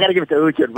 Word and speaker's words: Uhtred: Uhtred.